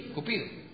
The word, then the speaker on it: Cupido: En tiempos romanos por Venus y Cúpido, o Cupido, ¿cómo se dice? Cupido.